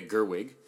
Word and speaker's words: Gerwig: I had an extra G by mistake, but it's Greta Gerwig.